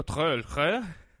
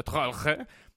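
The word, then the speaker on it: Troeltsch: Io, eh, io ero tornato dall'America con un amico Trölcher eh, amico che voi tutti pronunciate malissimo, si pronuncia Trolche, ehm, e, e, era amico mio, mi ha detto, guarda, America, America fonte futuro, America fonte del futuro.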